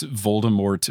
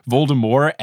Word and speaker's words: Voldemort: Look, all of those people that do that are the same people that pronounce Voldemort Voldemort, which I know that it's French or whatever, so the T is silent, but look, in every single movie, they say Voldemort, and I know that in the first couple of audiobooks, they don't say the T, but J.K.